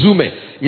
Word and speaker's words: zume: That word "living" is the Greek word zoome. Z-U-M-E. It's spelled Z-U-M-E, zoome.